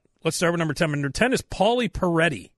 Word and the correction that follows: Perrette: Is that how it's pronounced? – I think it's just Perrette.